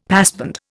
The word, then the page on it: passband: Indian